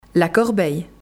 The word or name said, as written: la corbeille